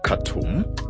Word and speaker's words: Khartoum: Vendors, farmer, farmers, vendors and farmers suffer amid rising prices and fuel shortages north of Khartoum. I said that in the, I said that right in the first try.